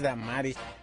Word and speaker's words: Damaris: Porque no tiene acento en la I, ¿verdad? Es Damaris.